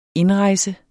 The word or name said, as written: indrejse